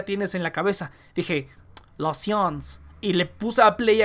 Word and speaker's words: science: Entonces, es science, en inglés lo pronuncia science, pero en francés es la, science.